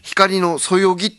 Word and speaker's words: 光のそよぎ: 光のそよぎって読むんでしょ。 – あ、そうですね、